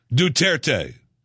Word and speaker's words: Duterte: Do you have the pronunciation of Duarte? Duterte. President Rodrigo Duterte.